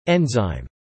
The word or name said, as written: enzyme